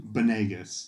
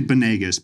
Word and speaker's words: Banegas: Banegas. – Can you tell us? – It's actually Banegas, but if bananas is easier for you, let that ride.